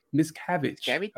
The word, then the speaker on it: Miscavige: So the plaintiffs in this case had tried to serve, what is it, Miscavige? Is that how you say his name? – Miscavige. – Of course, it has to be fancy.